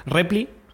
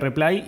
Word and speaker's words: reply: Que me acuerdo es Repli, que las, que pronuncio como se me canta. – Repli, sí. – Em, paste, que en, vez de, perdón, reply sería reply y paste sería paste.